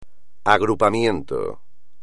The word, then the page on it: agrupamiento: espagnol